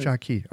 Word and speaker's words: Jia Qi: This idea that the Xie Qi, the Jia Qi, is that how you say it? – Mm-hmm. – Jia Qi. Okay.